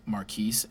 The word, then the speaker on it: Marquis: So my name is Marquise, pronounced Marquise and spelled M-A-R-Q-U-I-S.